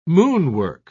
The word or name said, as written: moonwalk